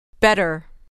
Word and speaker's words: better: Better. Better. Der. Better. Better. – در اینجا و در خیلی موارد ت مخصوصا در تلفظ آمریکایی به صورت د نازک تلفظ میشه.